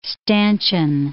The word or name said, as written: stanchion